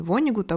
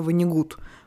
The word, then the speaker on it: Вонигут: Когда я занималась русским языком у Михаила Абрамовича Штудинера, автора словаря образцового ударения, он мне говорил, что по-русски правильнее говорить не Вонигут, а Вонигут, потому что это фамилия немецкого происхождения.